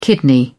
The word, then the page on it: kidney: British English